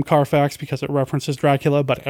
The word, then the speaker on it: Carfax: Seeing the police wander through Carfax Abbey in complete bewilderment was truly compelling. Is that supposed to be said somehow Carfax? – No, you said it right.